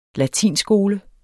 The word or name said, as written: latinskole